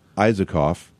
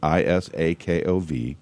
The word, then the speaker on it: Isakov: Allen Isakov, I believe is how you pronounce it, Isakov.